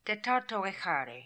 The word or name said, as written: Tetartoehary